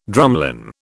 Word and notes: drumlin: British English

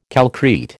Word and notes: calcrete: American English

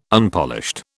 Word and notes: Unpolished: British English

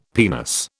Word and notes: penis: British English

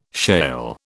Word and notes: shale: British English

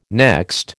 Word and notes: next: American English

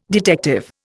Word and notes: detective: Indian

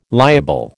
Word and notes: liable: American English

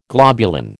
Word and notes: globulin: American English

